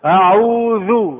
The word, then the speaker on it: أعوذ: أعوذ ذا أذ، فهناك فرق. تعرفون ما معنى أعوذ؟ ما معنى أعوذ؟ أحتاج. لكن أعوذ ألوذ وأتحصن بالله عز وجل من الشيطان. فلا يجوز لك أن تنطق الذال زايا، عيب عليكم يا أهل العربية، يا عرب، ولا أنتم عجم؟